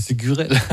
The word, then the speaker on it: Ségurel: Vas-y, t'as écouté Ségurel. – Oui, il est prononcé comme ça, effectivement.